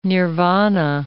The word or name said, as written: nirvana